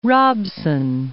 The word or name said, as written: Robson, Mount